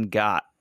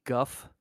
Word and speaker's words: Gogh: How about Van got it. Like Van Gogh. – And Van Wendt. – Van Wendt. – Van Wendt. Vincent Van Wendt. You know, I think his name is actually pronounced Guff, not Go.